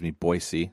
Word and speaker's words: Boise: It's a soft S. it's important to get it right. – Do they get sensitive about it? Boise? They want Boise? – I don't know that they do, but someone who's from Idaho told me a long time ago that it was kind of an annoyance. Like, it's not Boise with a Z, that it's Boise. So it's an S. You pronounce the S like an S.